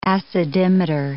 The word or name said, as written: acidimeter